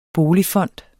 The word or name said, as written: boligfond